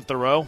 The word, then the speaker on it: Theroux: I don't know how to say this guy's name. Justin Theroux. Is that how it's pronounced? – Oh, yeah. He was you said it right.